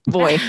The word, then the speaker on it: VOY: You mean Voy? – Voy, that's right. We say it phonetically now.